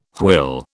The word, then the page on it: hwyl: British English